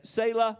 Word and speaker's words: Selah: It's S-E-L-A-H. And Selah, some say it Selah, however you want to pronounce it.